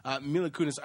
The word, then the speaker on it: Mila Kunis: Mila Kunis.